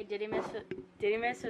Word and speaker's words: Didymus: By the way, Didymus was right, because I listen to my Bible also when I read it, and that's how they pronounce it.